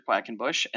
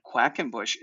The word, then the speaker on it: Quackenbush: So my name is Jared Quackenbush, and that is my Instagram handle. So it's at Jared, J-A-R-E-D. And Quackenbush is spelled just how it sounds, folks. So it's Quackenbush.